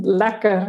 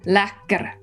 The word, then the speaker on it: lekker: Well, I think to that, all I have to say is lekker. Am I saying that right? – Yeah.